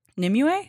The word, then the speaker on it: Nimue: Nimue? – Thank you.